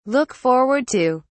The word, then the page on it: look forward to: British English